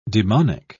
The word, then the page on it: daemonic: British English